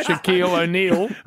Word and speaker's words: Shaquille O'Neal: To a break, because you chatted to Shaquille Eel. – Oh, Shaquille, did I? – Got to say it correctly. Yeah. Shaquille O'Neal.